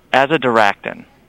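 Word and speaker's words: azadirachtin: It's called azadiractin, and I think I'm saying that right. That compound name, right? – Yes, but you're right.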